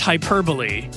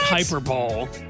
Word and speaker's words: hyperbole: That's hyperbole. Yes, it's what? not hyperball.